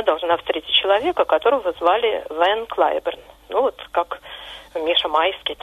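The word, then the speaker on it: Van Cliburn: Его звали, ну вот в английском произношении, Харви Лейвен Клайберн. Ван Клайберн – это не искаженное голландское Ван, а уменьшительное от его второго имени Лейвен.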